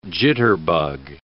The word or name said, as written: jitterbug